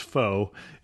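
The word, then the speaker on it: phở: And I think you said it correctly, but this is the one that in English is spelled P-H-O, but it's not right. – It's not pronounced pho, it's pronounced pho. – Right, almost like fur with the R slurred. Yes, that's how it's pronounced.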